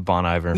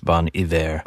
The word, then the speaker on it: Bon Iver: It's not Ivor, like Ivor's fish. – I mean, you could call him Bon Ivor. – You can say Bon Ivor. – But it's pronounced Bon Iver.